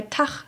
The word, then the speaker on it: Tach: Zu diesem Dialekt gehören natürlich auch Elemente aus dem Niederdeutschen, wie das als ch ausgesprochene g, wie etwa bei Tach statt Tag,